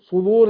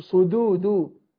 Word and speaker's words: صدور: الذي يوس شوف الهمس، الذي يوسوس في صدود، ما هو حد يقول لي صدور، صدور الناس، صدور، ينطق الدال ضاد، ده خطأ، اخلصها اقول صدود، صدور الناس، صدور.